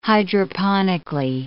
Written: hydroponically

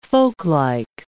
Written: folklike

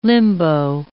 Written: limbo

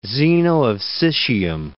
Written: Zeno of Citium

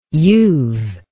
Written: youve

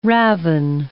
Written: ravin

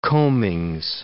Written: combings

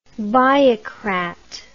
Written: biocrat

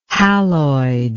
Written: haloid

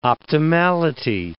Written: optimality

